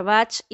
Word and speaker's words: Bach: Este sistema de 38 flores, que fue creado por el doctor inglés Edward Bach y se pronuncia así con la CH al final, así que acordate, no lo pronuncias como el, como el compositor de música clásica.